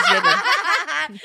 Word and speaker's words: Jadyn: I'm Jaden. – Let's go. – My name is Jaden. – How do you spell it? – J-A-D-Y-N.